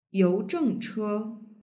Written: youzhengche

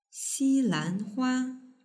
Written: xilanhua

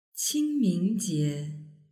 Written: qingmingjie

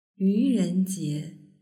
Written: yurenjie

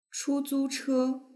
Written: chuzuche

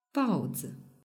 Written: baozi

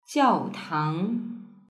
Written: jiaotang

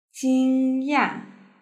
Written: jingya

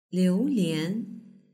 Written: liulian

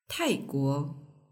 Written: taiguo

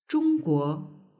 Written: zhongguo

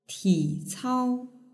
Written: ticao

